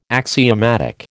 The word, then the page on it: axiomatic: American English